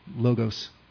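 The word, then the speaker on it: logos (λόγος): And the word used there in Greek is logos, usually pronounced logos.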